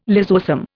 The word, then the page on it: lysosome: Indian